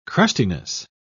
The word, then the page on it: crustiness: British English